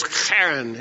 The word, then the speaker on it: Haran: And so the way they would pronounce Haran was Charan.